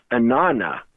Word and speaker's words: Inanna: And in Aramaic, it sounds like this, Inanna, which is actually one word, Anna, said twice, Anna, Anna. And when I look on the page, I see Anna, Anna. But the way we pronounce that the middle ah sound drops and it becomes Inanna, which intriguingly is the ancient Sumerian goddess of love, fertility, and warfare.